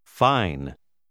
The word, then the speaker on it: fine: Fine. – Ha notato la lettera E alla fine di questa parola? La E finale tende a cambiare il suono della vocale precedente, però la stessa E non si pronuncia.